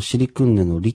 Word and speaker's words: シリクンネ: ただ、実はこれ日本語にない発音でして、シリクンネのリっていうのはリとルの間のような言い方をしますので、うん、表記としてはルでもリでも、どちらでもいいんですけれども、この場合、あえて、ルとリを分けてみました。